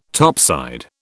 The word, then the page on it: topside: British English